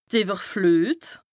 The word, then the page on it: d' Ìwwerfluet: Bas-Rhin; Strasbourg